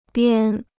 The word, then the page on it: 边: biān